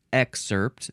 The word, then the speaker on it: excerpt: The next word is the noun excerpt. This is the one that I pronounce with an emphasis on the first syllable. Excerpt or excerpt.